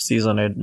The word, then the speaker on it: SZNEDD: Or you can call me by my stage name, Seasoned. That's SZNEDD. That's also my username in YouTube. Channel, that's my YouTube channel, Seasoned. It's pronounced Seasoned, not sneezed.